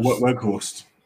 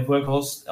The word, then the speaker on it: Weghorst: Weghorst. – Yeah, Weghorst.